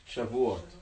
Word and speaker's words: Shavuot: Shavuot. – Shavuot.